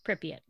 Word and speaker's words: Pripyat: But yeah, it was Pripyat. Is that how you say it? I've been reading it pronounced wrong in my head this whole time. So yeah, Pripyat.